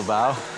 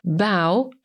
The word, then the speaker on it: bow: Little bow. And away. – Bow to ukłon. Zapisujemy to słowo jako B-O-W i warto pamiętać, że ono ma jeszcze inną wymowę, bow, i zmienia wtedy znaczenie, bo jest to albo kokarda, albo łuk, z którego się strzela, a nie na przykład łuk triumfalny, czyli coś w kształcie łuku.